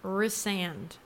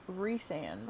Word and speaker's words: Rhysand: I'm not spoiling, but I'm telling you guys, this is how you pronounce his name. It's not Rhysand. It is Rhysand. Okay? Thank you. – If you're from North Carolina, it's more like Rhysand.